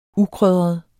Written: ukrydret

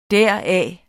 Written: deraf

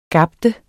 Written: gabe

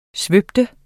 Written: svøbe

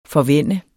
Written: forvænne

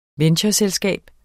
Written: ventureselskab

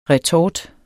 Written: retort

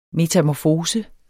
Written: METAMORFOSER

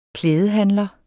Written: klædehandler

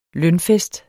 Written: lønfest

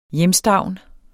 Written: hjemstavn

